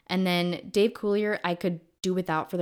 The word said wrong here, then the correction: Coulier: He's Canadian. It's Coulier. – Coulier, I'm sorry. Is that French or something? – It's French.